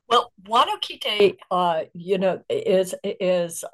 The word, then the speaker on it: Huanoquite: Um, it's Huanoquite.